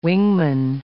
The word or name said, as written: wingman